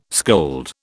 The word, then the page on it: scold: British English